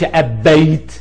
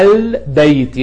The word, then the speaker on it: البيت: أبغي الهمزة تقول الإبل، أبغي الباء تقول البيت. البيت ما بتقولش أبيت، لا البيت، يبقى اللام ساكنة.